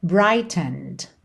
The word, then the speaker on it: brightened: E essa próxima linha aqui, ficou muito interessante essa palavra brightened, brightened. Brighten é o verbo, certo? Ele aqui tá no passado, mas esse é da terminação ed não é pronunciado.